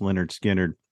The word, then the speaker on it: Lynyrd Skynyrd: Well, you know, their first album, which is what we're going to talk about here today, pronounced Lynyrd Skynyrd, is one of those ones where pretty much everything on it was almost a radio single.